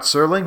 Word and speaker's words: Serling: Wait, Rod Serling, right? That's how you pronounce his name, Rod Serling? – Yes, yeah. – I said Rod Sterling there. I get those confused.